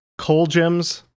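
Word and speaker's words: Colgems: Indeed, from November of 1967 on the, is it pronounced Coal Gems label. – That's how I pronounce it. – Yeah, I think that's it.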